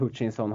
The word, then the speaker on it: Hutchinson: Eh, Hutchinson, Hutchinson, uttalen är on point.